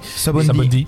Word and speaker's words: Sabaody: Pour faire simple, l'histoire de One Piece Odyssey se déroule dans l'arc Retour de Sabaody. – Sabaody. – Sabaody.